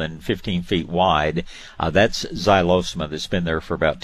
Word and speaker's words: xylosma: Then probably my first choice would be something called a xylosma. It's spelled like xylophone. It's spelled X-Y-L-O-S-M-A. Xylosma, oh gosh,